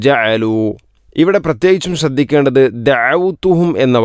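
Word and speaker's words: ജഅലു (جَعَلُوا): ലിതഗ്ഫിറ ലഹും ജഅലു അസ്വാബിഅഹും ജഅലു, ഇവിടെയും ഐനിൻ്റെ ഉച്ചാരണം ശ്രദ്ധിക്കേണ്ടതാണ്. ജഅലു ജഅലു എന്ന രൂപത്തിൽ ആവരുത്, ഹംസയല്ല. ജ ജ ഐൻ തൊണ്ടയുടെ മധ്യഭാഗം.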